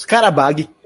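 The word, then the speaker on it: Qarabağ: cara, é, é, é, que o G é diferente. O G tem um, um chapéu ao contrário. Mas o Karabag, não, o G não se pronuncia. Digamos Karabag.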